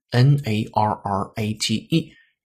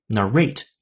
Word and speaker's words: narrate: 这个关键词是 N A R R A T E。有两种读音方式，一种可以读成 narrate，一种可以读成 narrate，两种都可以表示讲述。